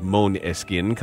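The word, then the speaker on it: Måneskin: Monaskin are something far more important, an alternative. An alternative to what, exactly, is the question. The unlikely global ascent of Monaskin, the word is Danish for moonlight, pronounced Moneskin,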